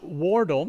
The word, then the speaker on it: Wardle: which is a play on words of his last name, Wardle, which rhymes with turtle.